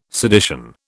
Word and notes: sedition: British English

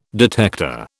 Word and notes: detector: British English

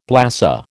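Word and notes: blasé: American English

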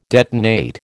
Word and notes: detonate: American English

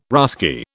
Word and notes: Russki: British English

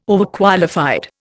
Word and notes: Overqualified: Indian

